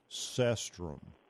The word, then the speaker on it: cestrum: and it's called yellow cestrum. – Yellow cestrum. – Yeah, it's spelled C-E-S-T-R-U-M, yellow cestrum.